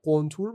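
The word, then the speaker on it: قنطور: گفتن سلام فکر کنم تلفظ صحیح کلمه قنتور باشه که اسم یه ستاره نسبتا نزدیک به زمین رو از هم روی همین اسم گذاشتن آلفای قنتوروس آره.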